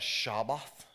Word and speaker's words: Shabbat: I think you pronounce that Shabbat, if I can really slaughter the Hebrew language. But that's kind of how it's pronounced in that language.